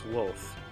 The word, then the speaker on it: Wolfe: My name is James Wolfe. That's spelled W-O-L-F-E.